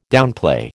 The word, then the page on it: downplay: American English